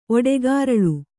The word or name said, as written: ಒಡೆಗಾರಳು